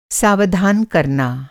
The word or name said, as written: सावधान